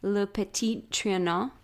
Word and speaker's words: Le Petit Trianon: It's the, Kate, correct me if I'm pronouncing this wrong, Le Petit Trianon. – That sounds right to me.